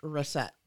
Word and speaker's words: Racette: W-E-N-D-E-L-L. – Hold on. – W-E-N-D-E-L-L. Last name R-A-C-E-T-T-E. And it's pronounced Rosette.